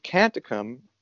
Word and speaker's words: Canticum: So the group, Canticum, by the way, that is a Latin word that's probably pronounced canticum correctly, but we're all say canticum.